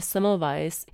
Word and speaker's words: Semmelweis: Goins taky špatně vyslovuje Semmelweisovy jméno s dvojitým V, co by mělo vyslovat jako anglické jednoduché V.